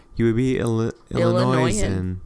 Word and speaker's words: Illinoisan: It's not Illinoisan because the S is silent. Illinoisan.